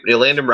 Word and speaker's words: Elandon: Kyle Van Noy and Elandon, am I pronouncing that name right? Elandon Roberts as their middle linebacker, their Mike linebacker. And you said it perfectly.